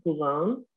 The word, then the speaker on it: Boulogne: You can find me by Dr. Pat Boulogne, and my last name is spelled B as in boy, O-U-L-O-G-N-E.